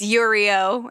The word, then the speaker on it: Yurio: Yurio. – Rhymes with Cheerio. – No, it doesn't. – Yurio Cheerio. – One's a and one's a ear. – Why do you want to prove me wrong always? Cheerio, Yurio. – Okay. – It rhymes. – Okay, yeah, I guess so.